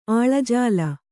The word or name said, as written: ಆಲಾಜೋಲಿ